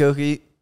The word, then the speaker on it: Oelke: Underscore O-E-L-K-E. The L is silent because I don't take that shit.